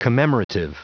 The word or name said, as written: commemorative